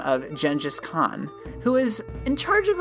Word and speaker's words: Genghis Khan: And you'd be right, except Genghis Khan, and yes, that is how you pronounce it, I looked it up, was a lot more complicated than genocidal maniac with a lot of wives.